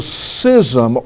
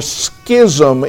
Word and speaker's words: schism: First of all, so that there will be no schism or schism in the body. I checked the pronunciation. You could pronounce it either way.